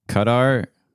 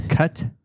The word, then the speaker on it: Qatar: Qatar. – Oh, you said it right. It's Qatar.